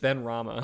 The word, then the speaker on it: Benrahma: No, it's just Benrahma. – Oh, okay. So the H is silent.